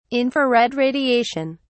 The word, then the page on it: infrared radiation: British English